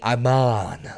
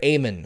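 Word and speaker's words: Amann: I keep saying Iman, like Armando, Alejandro, Estrada. But Dr. Amon,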